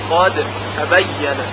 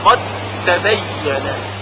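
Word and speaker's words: قد تبين: و یا در این مثال قادر تبینه در آیات کلی قاد تبینه ادغام میکنیم قاد تبینه یک تی مشدد شنیده میشه قاد تبینه.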